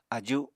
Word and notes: aju7: apatani

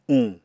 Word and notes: uñ: Apatani

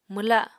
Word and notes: mwla: Apatani

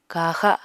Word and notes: kaxa: Apatani

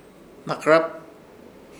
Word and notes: Hnakɨrap: Ishir Chamacoco

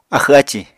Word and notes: axw ácì: apatani

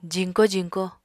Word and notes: jiñko jiñko: apatani

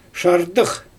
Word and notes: ʃardɯx: Xyzyl

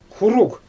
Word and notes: xuruɣ: Xyzyl

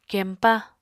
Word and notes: kempa: Apatani

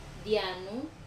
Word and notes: anu: Wamesa

